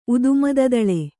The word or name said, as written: ಉದುಮದದಳೆ